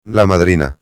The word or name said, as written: la madrina